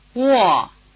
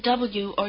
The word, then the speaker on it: Wo: The combination WO or UO is pronounced rather like the beginning of the English word wall.